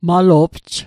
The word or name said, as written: malo'pj